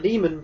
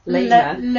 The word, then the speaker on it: Lehman: It's a bank called the Lehman Brothers. – You pronounced that wrong. – How much is it pronounced? – Lehman. – The Lehman Brothers.